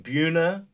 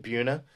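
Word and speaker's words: Buena: So the state championship final had Pequannock Township High School and Buna, that's how we pronounce it, Buna.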